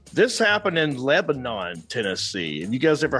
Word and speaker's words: Lebanon: They pronounce it Lebanon. If you ever been there, that's how they say it, Lebanon.